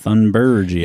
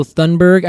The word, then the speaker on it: Thunberg: I think actually they might pronounce it Thunberg. – Is it German? – Swedish. It is... Named after Carl Peter Thunberg. – Okay. – Or Thunberg if you're from the United States of America.